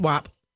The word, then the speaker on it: WAP: Rap, WAP, tap, sap. Okay, WAP. Got it. It's spelled W-A-P.